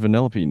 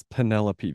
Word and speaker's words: Vanellope: And look at me, I said her name correctly this time. – Good job, I'm proud of you. – I swore up and down that her name was Penelope the whole game. Like, okay, that's a normal girl's name. I didn't think vanilla.